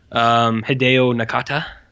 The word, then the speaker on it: Hideo Nakata: This is the guy who made Ringu, Hideo Nakata, I believe is how you say his name.